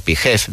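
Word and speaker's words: PHEV: la terminación PEF o PIGEF, dependiendo de cómo lo queramos pronunciar.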